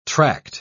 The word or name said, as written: tract